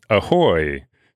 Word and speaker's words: ahoj: Well, if I'm going to do this hiking trip someday from Vienna to Prague, in Czech, goodbye is, it's spelled A-H-O-J, but it's pronounced ahoy.